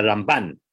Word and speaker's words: Ramban: But he would say the way you distinguish between them is that it's the Rambam and the Ramban, that the Ramban gets the stress on the second syllable and the Rambam gets the stress on the first syllable, so that you're not making a confusion.